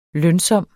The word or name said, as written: lønsom